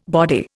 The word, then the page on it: body: Indian